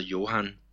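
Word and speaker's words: Hyoran: når man læser hans navn, men det, det udtales altså Johan.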